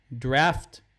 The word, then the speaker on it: draft: Pronounce the first D like a J, J, like jar, jelly, draft, draft.